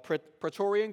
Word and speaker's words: Praetorian: Let me see if I said that right. Praetorian Guard, yes.